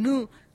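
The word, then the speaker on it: Yhú: Así que le pedimos el testimonio al padre Jorge Cóceres, que está en Nu, así se pronuncia en guaraní, pero bueno, es una localidad que se escribe YHUU, lo podemos pronunciar tranquilamente, que bueno, nos contaba esto.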